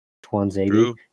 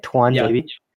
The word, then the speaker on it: Tuanzebe: He gave Axel Tuanzebe the secrets and Tuanzebe. – Is that how you say it? Tuanzebe. – Yeah. – T-U-A-N. Tuan. Yeah. Okay. – It's Tuan.